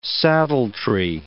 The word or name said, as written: saddletree